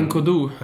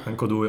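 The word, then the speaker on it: N'Koudou: Nu kan jag inte uttala hans namn. Kevin. – Ja, uh, Nkodu. Nkodu, ja precis.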